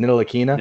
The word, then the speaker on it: Ntilikina: Frank Ntilikina. I can't pronounce it. Ntilikina.